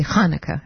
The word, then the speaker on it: Hanukkah: We don't have that sound in English, but you do have it in a number of languages. I think Greek has it, and Hebrew does have it. Happy Hanukkah is how you say it, and Merry Christmas in advance.